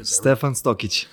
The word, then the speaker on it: Stefan Stokic: Stefan Stokic.